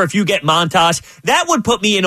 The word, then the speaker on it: Montas: Yeah, so they get Montas. That's how you say it, right? – Montas. – Frankie Montas.